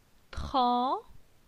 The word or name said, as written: Tran